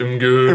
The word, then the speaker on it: Imgur: I'm pretty sure it's pronounced Imgur.